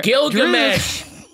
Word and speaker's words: Gilgamesh: Ajak offers to wipe Thena's memory, but you know, Glisham volunteers to look over her. – Gilgamesh. Glisham. Gilgamesh. – Gilgamesh volunteers to, honestly, it's spelt the way it's pronounced.